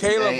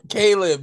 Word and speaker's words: Caleb: It's Caleb Williams. Say his name right. Why do you keep calling him Khalib? That's racist. – Wait, what's his name called? – Caleb, not Khalib.